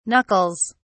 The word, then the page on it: knuckles: British English